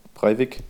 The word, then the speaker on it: Breivik: Wie spricht man seinen Namen aus? Breivik? – Breivik.